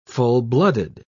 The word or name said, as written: full-blooded